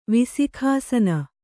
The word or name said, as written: ವಿಸಿಖಾಸನ